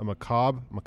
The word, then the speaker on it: macabre: A macabre. There's an E on the end. – It is. – E silent? – French fancy. – It's French fancy. Okay. – It's French. – Silent R-E. Yeah.